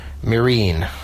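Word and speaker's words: Myrin: I always pronounced it wrong until I did At Midnight with her a couple of times, and Chris Hardwick pronounces it Marine, so now I know better.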